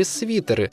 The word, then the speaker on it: свитер: Каким бы мягким он ни был, слово произносится твердо – «свитер».